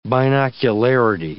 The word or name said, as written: binocularity